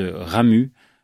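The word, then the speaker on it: Ramuz: C'est l'auteur suisse Ramu. Avec un Z à la fin, mais les Suisses ne prononcent pas le Z, ouais.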